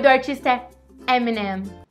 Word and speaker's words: Eminem: Olha bem, presta atenção na minha boca. Eminem. Eminem. É muito parecido com M&M.